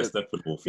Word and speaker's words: Steppenwolf: Yeah, Steppenwolf.